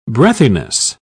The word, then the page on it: breathiness: British English